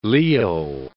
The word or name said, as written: Leo